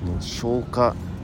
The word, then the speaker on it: 生花: か 3 パターンぐらい大きくあってどれがいいとか悪いじゃなくてそのスタイルの違いなんですけども僕個人的には消化消化消化消化あの生花って書いて生のお花って書いて消化って読むらしい